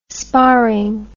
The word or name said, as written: sparring